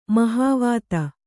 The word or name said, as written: ಮಹಾವಾತ